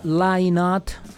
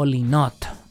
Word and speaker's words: Lynott: Pero hoy, específicamente hoy, le damos sentido al nacimiento de Phil Lynott, o Lynott, o Lynott. Reitero, se pronuncia de formas diferentes porque los nombres propios así son.